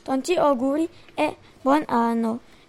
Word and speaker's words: tanti auguri e buon anno: Ça se prononce tanti auguri e buon anno.